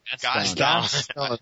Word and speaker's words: Gaston: Guston? I'm never getting his name right. – Gaston. Like Gaston. Gaston. – Gaston, okay. Gaston. – Pronounced with a French.